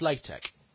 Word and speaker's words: LaTeX: Well, yeah, but LaTeX is pronounced LaTeX.